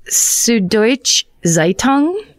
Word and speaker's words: Süddeutsche Zeitung: It looks like Süddeutsche Zeitung.